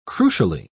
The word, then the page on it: crucially: British English